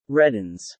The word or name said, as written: reddens